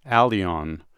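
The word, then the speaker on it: Älgen: I'm going to say it's pronounced Alion. – Oh. – Okay. – Oh, all right. – But it's not. It's spelled A with umlauts, L-G-E-N.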